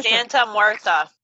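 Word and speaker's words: Santa Muerte: Oh, Santa Marita? – Santa Muerta. – Yeah, Santa Muerta.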